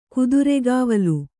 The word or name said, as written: ಕುದುರೆಗಾವಲು